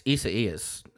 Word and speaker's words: Isaias: Yeah, it's Isaias. It's a Spanish-Latin derivative for the Hebrew name Isaiah.